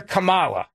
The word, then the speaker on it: Kamala: And if you, by the way, mispronounce Kamala Harris's name, like if you call her Kamala, because it's Kamala, and if you call her Kamala, or you say any other version of that, you are intentionally doing this to diminish her, okay?